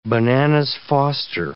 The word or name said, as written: bananas Foster